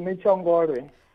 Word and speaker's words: Netshiongolwe: It's Netshiongolwe.